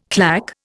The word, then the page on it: clack: Indian